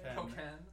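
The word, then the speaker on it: Pokkén: And I'm gonna keep calling it Pockin, but I don't know if that's its official pronunciation. – Pokin. – It's supposed to be like Tekken.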